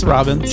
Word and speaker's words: Robbins: It's Robbins. – Just Robbins? – Just okay. – Like the way it looks.